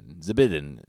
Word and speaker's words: Zbinden: His name is spelled Z-B-I-N-D-E-N. Zbidin.